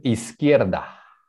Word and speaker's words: izquierda: Okay, so pueden repetir izquierda. – Izquierda. Izquierda. – Okay, so the U is silent. Izquierda.